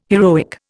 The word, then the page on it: heroic: Indian